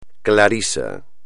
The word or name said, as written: clarisa